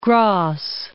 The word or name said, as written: Grasse